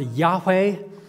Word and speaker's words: Yahweh: It's pronounced Yahweh, Yahweh. In the King James Bible, you find the word Jehovah, Jehovah.